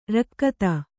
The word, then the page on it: ರಿಕ್ಥ: Kannada